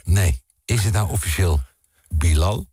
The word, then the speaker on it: Bilal: Want het is Bilal. Het is Bilal. Oh. Mensen zeggen Bilal, ja. Maar het is Bilal. – Bilal. – Bilal. – Bilal. – Ja, dus mensen kunnen het niet zo goed uitspreken.